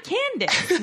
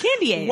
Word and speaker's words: Candiace: It's Candace, but it's spelled... – Like candy, like C-A-N-D-I-A-C-E. That's not Candace. Yeah. That's Candy Ace.